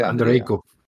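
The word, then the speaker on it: Andreyko: Torso que coescribe con Marc Andrienko. Marca, ¿Cómo se dice? Andreico. Andreico.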